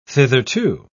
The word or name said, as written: thitherto